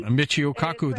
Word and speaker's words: Michio Kaku: Michio Kaku, the physicist.